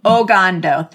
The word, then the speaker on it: Ogando: As you know, my name is Dr. Mary Meduna Gross, and today we are joined by Dr. Monica Ogando. Did I say that correctly? – Ogando, no R in it, just Ogando. – Ogando, thank you. Sometimes the R just slipped in.